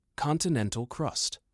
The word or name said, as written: Continental Crust